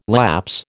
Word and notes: lapse: American English

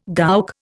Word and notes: gowk: Indian

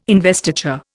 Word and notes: investiture: Indian

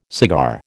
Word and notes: cigar: American English

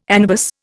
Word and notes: anabas: Indian

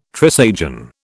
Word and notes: Trisagion: British English

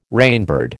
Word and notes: rainbird: American English

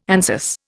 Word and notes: Anzus: Indian

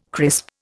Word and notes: crisp: Indian

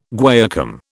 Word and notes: guaiacum: British English

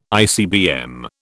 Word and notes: ICBM: British English

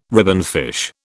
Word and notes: ribbonfish: British English

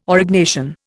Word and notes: Aurignacian: Indian

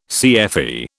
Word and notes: CFE: British English

